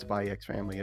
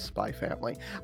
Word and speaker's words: Spy x Family: It's not Spy X Family, it's Spy Family.